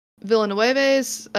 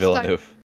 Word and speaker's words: Villeneuve: The actor next appears in Dennis Villeneuve's Star Pact. – Villeneuve. – I don't know. I'm sorry. I'm awful with pronunciation. What is his last name? – Villeneuve.